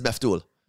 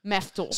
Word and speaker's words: maftoul: This one is meftul. – Meftul. – So I would spell it M-A-F-T-O-U-L or T-O-O-L.